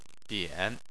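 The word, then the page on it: bian: tone 3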